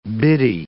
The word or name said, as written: biddy